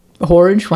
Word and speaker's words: Horridge: Horridge, rhymes with his porridge.